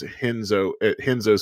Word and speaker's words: Renzo: It's if you go to UFC, they have the Pride FC free fight, Kazushi Sakuraba versus Henzo spelled with the R-E-N-Z-O. And in Brazil, they pronounce R's with the H, Henzo Gracie.